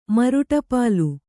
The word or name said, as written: ಮರುಟಪಾಲು